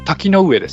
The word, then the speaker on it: 滝上: 滝上町って読むんですかこれは滝の上です滝の上はい、うん、ここで作っている和ハッカを使用だつですはいへえうっかり坊主さん、初投稿、ありがとうございます。